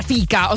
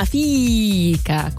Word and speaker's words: fika: E anche fika, non si pronuncia fika, ho scoperto, ma fika con 6000 i.